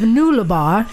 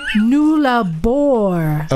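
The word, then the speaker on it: Nullarbor: Just had to pull you up on your pronunciation of Nullabor. Try Nullabor.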